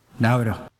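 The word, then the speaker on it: Naoero: Prezident Adeang to vyslovuje něco mezi Nauro a Nauero. To O na konci možná dokonce jako by skoro bylo třeba přehláskované, něco jako v němčině.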